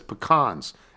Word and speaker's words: pecans: By the way, everyone I know in North Carolina says pecans, as in James Caan.